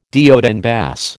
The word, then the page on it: de haut en bas: American English